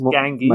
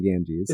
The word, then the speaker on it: Ganges: I always Ganges. – My brain is like, pronounce it with an H because it's Spanish, but it's not that way. It's just Rajas of the Ganges.